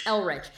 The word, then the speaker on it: Elrich: Elrich.